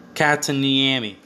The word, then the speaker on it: Kotkaniemi: Kotkaniemi, that's how you say it.